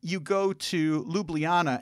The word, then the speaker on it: Ljubljana: Ljubljana. – Ljubljana.